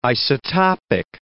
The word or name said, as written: isotopic